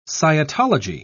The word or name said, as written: scientology